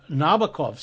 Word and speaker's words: Nabokov: what Nabokov said mm-hmm. is it rhymes with knockoff.